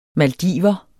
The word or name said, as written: maldiver